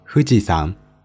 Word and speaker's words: 富士山: 富士山，日语念作 Fujisan，它位于山梨县南部和静冈县北部交界处，海拔三千七百七十六米。